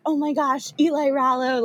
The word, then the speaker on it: Eli Rallo: Is it Eli Rallo? Am I saying that right? – Yes.